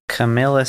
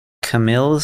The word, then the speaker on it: Camille: Camilla Sims. – What? – Camille Sims. – Camille, okay. Camille or Camilla? – Well, it's spelled C-A-M-I-L-L-E, so I'm assuming Camille. It's Camille.